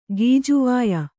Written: ಗಿಜವಾಯಿ

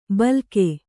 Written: ಭೀಲುಕ

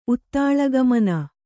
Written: ಉತ್ತಾಳಗಮನ

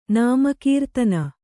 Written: ನಾಮಕೀರ್ತನ

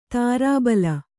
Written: ದೋರ್ಬಲಿ

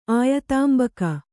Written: ಆಯತಾಂಬಕಿ

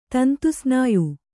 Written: ತಂತುಸ್ನಾಯು

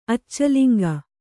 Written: ಅಚ್ಚಲಿಂಗ

